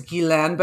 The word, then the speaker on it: Ghislaine: Is it, yeah, you pronounce her name Ghislaine Maxwell. – The French pronunciation is Ghislaine, but I don't know that she even uses that.